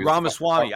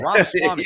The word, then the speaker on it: Ramaswamy: Ramaswamy. I don't know why I can't pronounce that guy. – Yeah, Ramaswamy. You threw me off when you mispronounced.